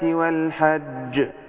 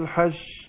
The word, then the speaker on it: الحج: كويس لكن أنا عايز برضو ناخد بال عشان كثير بيقع في الجيم هنا المشددة اللي كتير بينطقوا كلمة الحج تنطقتها كويس ربنا يبارك فيك لكن كتير بينطقوها خطأ سواء كان في القراءة أو ممكن واحد على المنبر يقول الحج اللي يطلعها شين خالص يبين اللي هو التفشي بيبقى في الشين بس مش في الجيم.